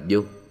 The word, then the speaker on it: vô: Sách Đại Học có câu nhi kim vong hỷ, mà chữ vong này đọc là vô.